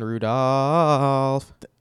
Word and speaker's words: Rudolph: You kill me. It's a silent L, man. – Mason Rudolph.